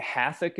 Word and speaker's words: Hathach: Is that, you pronounce that Hathach? – I think Hathach is fine. Whatever's Hathach, easiest.